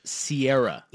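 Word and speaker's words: Ciara: Uh, Russell's wife is it's pronounced Sierra, like Sierra Mist.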